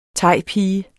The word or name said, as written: thaipige